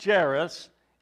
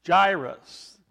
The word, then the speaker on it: Jairus: One of the synagogue officials named Jairus, now it's not pronounced Jairus, is gyrus.